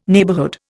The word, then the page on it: neighbourhood: Indian